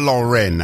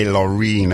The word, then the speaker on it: Loren: Aisha Loren, I did say Loreen earlier on, so Nadum, which simply translated means apologies for that little mispronunciation.